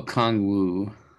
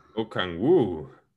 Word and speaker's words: Okongwu: Onyeka oh, Okongwu. Okongwu.